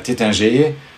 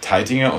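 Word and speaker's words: Taittinger: Viele Häuser haben deutsch klingende Namen, auch wenn die im Französischen ausgesprochen, wenn man das nicht so hört, Eizig oder Tétinger, aber Teitinger und Heizig sind ganz klar deutsche Namen und die Teitinger, glaube ich, österreichisch, muss ich mich korrigieren.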